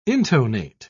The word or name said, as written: intonate